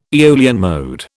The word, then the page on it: Aeolian mode: British English